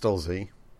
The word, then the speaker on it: Stolze: Yes, Stolzians, named after Greg Stolze. Stolz? Stolzo? How's it pronounced? – His name is pronounced Stolze.